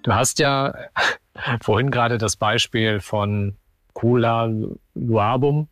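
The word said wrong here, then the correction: Umckaloabo: ich weiß gar nicht, ob ich es jetzt richtig ausgesprochen habe. – Umkaloabo.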